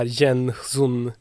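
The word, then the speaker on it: Jen-Hsun: Och hans riktiga namn är då ungefär Jen Sun. Men det var så svårt för amerikaner att uttala, så då skriver han bara som Jensen, för det kan folk uttala i alla fall.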